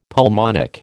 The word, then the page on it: pulmonic: American English